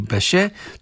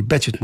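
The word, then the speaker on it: Bechet: Легендата на сопрано-саксофона Сидни Беше, той е Съдни Бечет му казват, но френското произношение Сидни Беше, може би заради ход клуба в Париж, където той беше една от големите фигури, и Сидни Беше е един от неговите идоли.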